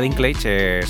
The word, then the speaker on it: Dinklage: A Peter Dinklage, creo que se llama Dinklage, se pronuncia bien así el apellido, el chiquitín, ya sabes quién es, el actor Peter Dinklage, dice que fue insoportable rodar en España.